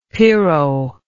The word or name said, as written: piro